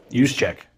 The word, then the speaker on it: Juszczyk: Juszczyk.